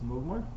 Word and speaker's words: Mugmar: What's Mugmar? Rhymes with Morgmart.